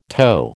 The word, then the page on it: toe: American English